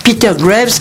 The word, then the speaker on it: Peter Graves: Et donc, il était avant Peter Graves, qui a été l'un des héros.